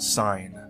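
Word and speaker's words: sine: What's sin? – It's pronounced sign.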